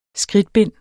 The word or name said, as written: skridtbind